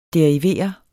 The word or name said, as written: derivere